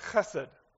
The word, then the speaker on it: chesed: It's the word that when you say it right, you spit on the neck of the person sitting in front of you. It's the word chesed. Chesed.